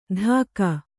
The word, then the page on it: ಧಾಕ: dhāka